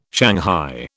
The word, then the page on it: shanghai: British English